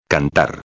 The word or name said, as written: cantar